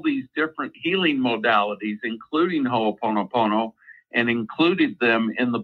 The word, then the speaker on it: Ho'oponopono: Is it the concept, the Hawaiian concept of, I think, Ho'oponopono? I can't pronounce that well. – Ho'oponopono. – Yes. – Ho'oponopono.